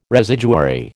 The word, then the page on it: residuary: American English